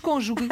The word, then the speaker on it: cônjuge: É verdade, também não gosto, mas já que temos de dar uma explicação, a palavra, a pronúncia correta, assim é que é cônjuge. – E com um acento circunflexo, não é? – Exatamente. É uma palavra esdrúxula. O que é uma palavra esdrúxula? O seu acento tónico recai na antepenúltima sílaba.